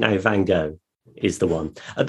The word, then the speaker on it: Van Gogh: It used to be, when I was a lad, it was Van Gogh. And then I think people became very pretentious about the pronunciation of foreign names. So it then became Van Gogh,